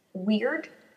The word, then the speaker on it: Wyrd: The proper name for this rune is Weird. It's spelled W-Y-R-D, weird.